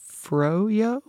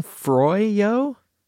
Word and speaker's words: froyo: Froyo? – Yeah. Frozen yogurt. Froyo. – Are you saying Froyo? – That's the O-Y, like, Froyo.